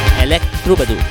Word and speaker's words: Electrobadur: Sorry, I think I mispronounced that. Like troubadour, you know troubadours? The troubadours of France, medieval France. I think that's what it means. But it's electric. Electrobadur.